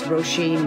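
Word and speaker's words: Róisín: Well, first of all, it's Roisin Machine, because that's my name.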